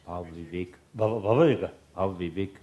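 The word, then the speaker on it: Bhavaviveka: So within Sangha, according to Tibetan translation, I think Arya Deva's text and Vivavega's, Bhava Viveka. – Usually, no difficulty. Today, I found it a little difficult to pronounce. Kasa. – Bhava Viveka.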